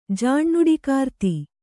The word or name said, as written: ಜಾಣ್ಣುಡಿಕಾರ್ತಿ